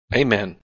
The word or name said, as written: Amen